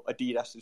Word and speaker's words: Adidas: Like, Adidas partnered, or Adidas, as you Americans say, did that thing with Bored Apes, for example.